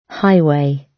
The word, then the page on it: highway: British English